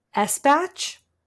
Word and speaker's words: Espach: So Alison Espach. I'm going to spell it in case you guys are curious and you haven't read it. It's E-S-P-A-C-H. So Espach or Espach, if it's a hard C-H, I don't know.